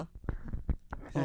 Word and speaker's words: Artaud: Bueno, ahora sí vamos con Poeta Negro, de Artaud. ¿Artaud? ¿Está bien pronunciado? ¿O Artois? – Artaud.